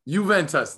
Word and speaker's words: Juventus: But anyway, Juventus, that's what it is.